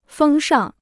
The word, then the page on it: 风尚: fēng shàng